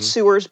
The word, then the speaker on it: sewers: It's spelled sewers, but I promise it's pronounced sewers.